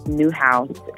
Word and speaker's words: Newhouse: New House is spelled just like it sounds. Like, I bought a new house.